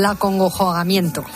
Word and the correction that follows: acongojamiento: No, es que has mezclado dos palabras. – Acongojamiento.